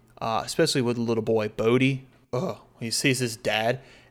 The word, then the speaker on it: Bode: Oh, what did you say before? – Bode. That's what I said name. Before. Okay. Because phonetically, that's how it's pronounced. – B-O-D-E. Bode.